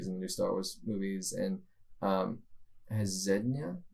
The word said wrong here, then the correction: Zendaya: is that how you pronounce her name? The girl from Greatest Showman, who played the acrobat. – Yeah, oh, Zendaya.